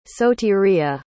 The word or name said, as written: sotiria